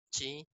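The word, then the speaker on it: chi (し): Japón, el Japón, el número 4 se pronuncia chi y chi también es muerte.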